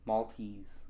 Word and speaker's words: Maltese: You're saying Mal. – From Malta. – Mall. – From Malta. Spain? Mall. – Maltese.